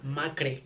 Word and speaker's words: Macre: Hay una marca que se llama Macre, así se llama Macre, con acento en la E, que vende productos veganos, que son de, de pepino, de, de moringa y así.